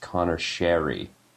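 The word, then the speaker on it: Conor Sheary: It's that Connor Sheary actually told people who announce in the NHL that he would rather his name be pronounced Connor Sheary.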